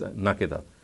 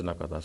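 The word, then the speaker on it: نَكِدًا: he says that some people have read the word as nakada, whereas the qiraatul amma is nakida. So in other words, he has said that even the morphological pronunciation of the word nakida is correct, recorded by the qiraatul amma as nakida, but there are some people of Medina who read it as nakada.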